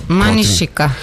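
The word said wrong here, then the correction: Menšík: Jakub Menšik i Alex de Minor.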